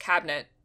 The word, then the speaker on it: cabinet: You kind of just forget the middle vowel. It's just cabinet. – Yeah. – No, I don't. – That's correct. – Cabinet. In American English, cabinet has two syllables.